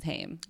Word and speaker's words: Haim: It's Haim, but it's spelled the same way. If you actually read it, it's Haim.